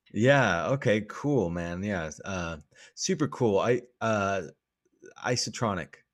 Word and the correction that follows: isochronic: Did I say it right? – Isochronic.